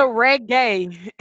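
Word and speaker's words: Regé: Shout out to Reggae.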